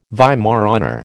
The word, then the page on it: Weimaraner: American English